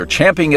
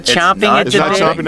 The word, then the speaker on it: champing: I'm sure you must take a lot of satisfaction because people are, they're champing at the bit, which is how you say that, by the way. – It's not chomping at the bit, huh? – No, everybody says chomping, but it's a racing term with racehorses.